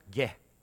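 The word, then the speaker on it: ge (γῆ): So, for example, little Greek word, teeny tiny little Greek word, two letters, gamma, eta. You pronounce it ge, ge.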